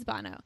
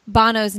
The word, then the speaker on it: Bono: Do I say that right? Bono? – Yes. – Yeah, absolutely. Okay. Yeah. – I always wonder if I say it right. It's Bono. – Yeah. Yeah, I suppose. Yeah. If you're looking at it, some people may pronounce it as Bono, but Bono is the correct.